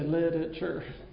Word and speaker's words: literature: It's a four-syllable word. Lots of people say literature, and it's not.